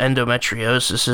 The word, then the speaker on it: endometriosis: Can you tell us what endometriosis is? – It's actually pronounced endometriosis.